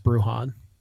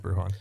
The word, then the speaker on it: Bruján: I think, by the way, it's pronounced Bruján. – So. Yeah, it's Bruján.